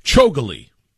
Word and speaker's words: Chougule: Chougule is what I'm going with as I look at the pronunciation guide. Akash Chougule is what I'm going to run with.